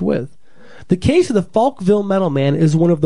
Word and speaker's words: Folkville: The Metal Man of Folkville, Alabama. Tricky, tricky name there. You gotta be careful how you say it. That's Folkville.